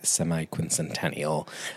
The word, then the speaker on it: semi-quincentennial: We have been exploring, as we've had conversations, the upcoming semi-quincentennial.